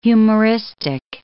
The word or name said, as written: humoristic